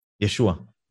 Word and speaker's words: ישוע: אתה יודע, עם כל הגדילה הזאת של השיחה, כן, אני רוצה להביא אותנו למקום שבו אנחנו צפים על המים, נדבר על יהושע. – ישוע. – יהושע. ישוע? איך, רגע, איך, איך, איך מבטאים את זה? – יוד שין ועין, תגיד את זה.